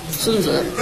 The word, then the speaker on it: 孙子: 大家知道咱们说到这里的拉点，庞统、庞士元说这三位军事家，孙子啊，咱们这字儿得念错了啊，孙子，咱们中国多音字啊，是吧？孙子啊，这子呢是对人的尊称，是吧？